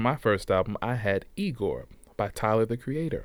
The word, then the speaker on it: IGOR: This is Igor. Pronounced Igor.